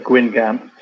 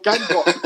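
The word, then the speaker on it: Guingamp: I can't say it properly, but Gwyn Gamp to us uneducated Englishmen. – Oh, Gangop. – Wait, how do you say it? Gangop.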